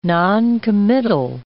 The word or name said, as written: noncommittal